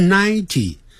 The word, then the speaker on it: ninety: 不是 ninety。 nine 那个嗯的音要念出来，ninety，一百，one hundred， hundred 啊，记住也可以讲 a hundred，你不用 one 也可以用 a 代替 a hundred，不是 a hundred， one hundred 就是一百，那个 hundred 就是百，加一个 one 的时候就一百， a hundred 也是一样。